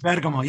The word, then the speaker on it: Bergamo: Bringing up Calabria, the greatest Italian-American Italian Canadian monument of our age was your album Bergamo. – Yeah. – Did I pronounce that correctly? – That's beautiful. – Bergamo, yes, very good.